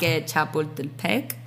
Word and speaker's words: Chapultepec: So they have a central park called, I'm going to butcher this, but Parque Chapultepec.